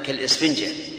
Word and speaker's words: كالإسفنجة: أنا أحسب بها ألف، أحسب بها همزة، كالإسفنجة، هكذا ننطق بها.